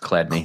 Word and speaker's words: Chladni: It's the Chaland knee plate? How do you pronounce that? – Oh, right. Yep. So that's the clad knee. That's pronounced clad knee.